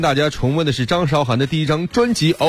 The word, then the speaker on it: 张韶涵: 有没有觉得这个张韶涵的名字听起来有点奇怪哈？大家可能是不是都习惯叫她张韶涵呢？其实她的名字正确的读音应该是张韶涵。